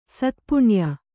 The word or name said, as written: ಸತ್ಪುಣ್ಯ